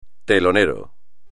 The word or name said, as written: telonero